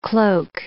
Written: cloak

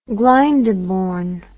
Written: glyndebourne

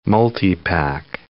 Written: multipack

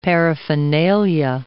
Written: paraphernalia

